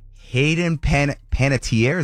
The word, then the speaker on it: Panettiere: Hey, 3 months after exposing Hollywood and the music industry for being the scumbags that they are, Hayden Panettiere, is that how you pronounce her name? – Yes.